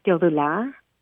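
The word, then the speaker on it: Teodula: It's Dodula.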